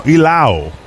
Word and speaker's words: Low: Triolo, Horwitz y Lau. No, bueno, se escribe Low, pero por alguna razón se pronuncia Lau, como bacalao.